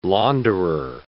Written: launderer

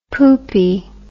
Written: poopie

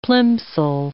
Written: plimsoll

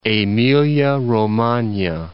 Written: Emilia-Romagna

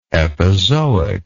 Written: epizoism

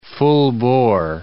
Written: full-bore

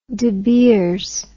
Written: De Beers

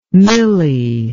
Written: milly

